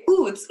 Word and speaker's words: Utz: we would always say ooh it's, because that's how you say it in Hebrew.